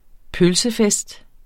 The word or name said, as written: pølsefest